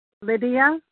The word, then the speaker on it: Lydia: Lydia V. from California. – Was that Hoodie? – Lydia, L-Y-D-I-A. – Lydia. Wow, I butchered that.